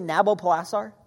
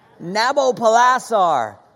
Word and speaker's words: Nabopolassar: You say Nabopolassar? All right, let's say it like you mean it. Nabopolassar.